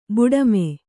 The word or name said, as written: ಬುಡುಮೆ